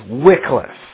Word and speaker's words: Wycliffe: The name John Wycliffe is pronounced with a short I, but it's spelt W-Y-C-L-I-F. Sometimes F-F, sometimes F-F-E, sometimes F-E. It, seriously, even in his own day, it went by various spellings. But it's pronounced Wycliffe.